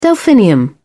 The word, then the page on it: delphinium: British English